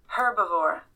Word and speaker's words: herbivore: About halfway through recording, we realized that herbivore does not have a silent H.